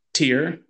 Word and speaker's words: Tyr: So you got Tuesday is for Tyr, or Tu is another way to pronounce it.